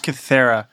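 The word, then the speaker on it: Kythera: Joining me is my co-host and brother, hailing from Katheria Island. – It's pronounced Kithera.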